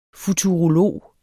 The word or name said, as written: FUTUROLOGER